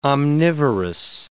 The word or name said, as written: omnivorous